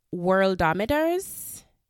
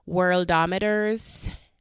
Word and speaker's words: Worldometers: Because all of a sudden I moved to the States and my T's don't sound anymore. Worldometers. Worldometers. Like the other day I caught myself saying water.